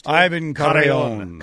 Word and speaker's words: Carrion: You gotta roll the R. Can you roll your R's? – Ivan Carrion.